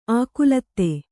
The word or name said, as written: ಆಕುಲತ್ತೆ